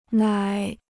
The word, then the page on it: 奶: nǎi